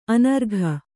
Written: ಅನರ್ಘ

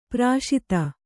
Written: ಪಾರ್ಷದ

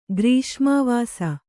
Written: ಗ್ರೀಷ್ಮಾವಾಸ

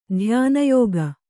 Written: ಧ್ಯಾನಯೋಗಿ

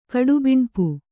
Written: ಕಡುಬಿಣ್ಪು

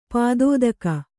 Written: ಪದಾತಿಕ

